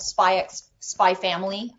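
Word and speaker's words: Spy x Family: But the cruise, the food in the restaurant was a buffet thing, and it was all Spy Family. I guess you don't pronounce the X. I've been doing it wrong this whole time.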